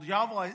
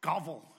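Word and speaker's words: Gävle: The Yovel Goat is part of a tradition in a town which is called Yovel. It starts with a G, G-A-L-V-E. Govel, I guess, would be the way you would pronounce it if that G wasn't silenced. And it's in Sweden, a small town in Sweden.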